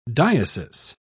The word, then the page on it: diesis: British English